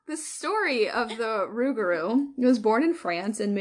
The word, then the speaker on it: Rougarou: The ragaroo. Now I'm getting all confused because there's so many different names for this thing. Yeah, because some of them are Rougarou, but you pronounce it Ragaroo.